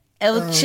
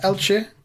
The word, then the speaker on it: Elche: Elche. – There you go. That's a good pronunciation, actually. Yeah, Elche.